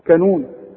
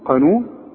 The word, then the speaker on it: قانون: عمرك ما تقول على القانون كانون تقول كانون قانون